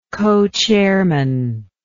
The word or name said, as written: cochairman